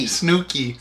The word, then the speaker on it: Snooki: Snoochie? – Snooki. – Snooki. – Smelled, smelled just like it's spelled. – That's exactly. Smells just like it's spelled. Yeah. You know, it's Snooki.